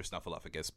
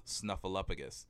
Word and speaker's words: Snuffleupagus: also performed Biff, one half of the two-headed monster, and was the OG Mr. Snuffleupagus before Martin P.